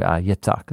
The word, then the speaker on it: Yitzhak: And it begins by introducing us to saying, these are the birth generations of Isaac, or Yitzhak is how you say his name in Hebrew.